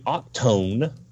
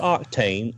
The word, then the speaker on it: Octone: The next picture is going to be Octone. Not Octane, Octone. And please tell me that's a misspell. – No, that is from Hasbro. That is the way it is being pronounced.